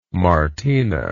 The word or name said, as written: martina